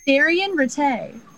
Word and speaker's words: Daryen Ratte: And she says, it's pronounced Ratte. It's French.